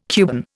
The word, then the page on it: Cuban: Indian